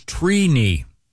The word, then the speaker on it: Trini: The correct pronunciation is Trini. – Wow. – Trini. – Like T-R-E-E. – Like weenie. – Yeah, I... – And so is it phonetically T-R-E-E... K-N-I? – Yeah, it's, phonetically it is T-R-E-E.